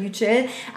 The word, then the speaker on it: Yücel: Yücel, ich, glaub, ich hoffe, ich richtig ausgesprochen, nicht Yücel, sondern Yücel.